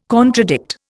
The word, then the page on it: contradict: Indian